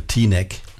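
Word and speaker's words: Teaneck: Et donc ça vient de sortir et nous venons d'écouter un morceau qui s'appelle Tinek. Je sais pas si c'est bien prononcé comme ça, mais... – Comment? – T-neck? – T-neck! Le T-neck. T-neck. Une chanson de Cannonball Adderley qui s'appelle T-neck.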